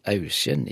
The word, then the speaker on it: Auskin: Ja, dette er et gardsnavn i Verdal, i Vukku, som er normert Auskin på kart og uttalt Auskjen i dialekten.